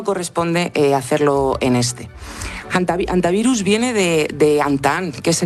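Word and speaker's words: hantavirus: No es infrecuente oírla quizá de ambas formas, pero lo adecuado es en efecto dejarla muda, decir antivirus y no aspirarla, jantavirus, ni nada similar.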